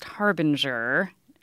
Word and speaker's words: harbinger: The word is spelled H-A-R-B-I-N-G-E-R. How do you say this word? – Now, this one I actually know is pronounced harbinger. – And that is how Sharon pronounces it. And Sharon wrote, "My husband Bob disagrees and said it is a hard G, as in goat."